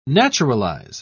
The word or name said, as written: naturalize